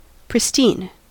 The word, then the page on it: pristine: American English